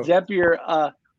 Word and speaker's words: Zapier: And the company, it's interesting because Zapier, pronounced it right that time. – There you go. – Zapier,